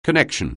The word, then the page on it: connection: anglais